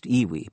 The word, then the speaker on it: EWI: The electronic wind instrument, EWI, pronounced Iwi, by the way, is another story.